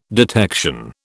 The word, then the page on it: detection: British English